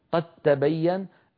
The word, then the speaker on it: قد تبين: قد تبين الرشد من الغي في الآية 256، قد تبين أدغمنا الدالة في التاء فصار النطق بتاء مشددة لا يبقى للدال أثر، لما؟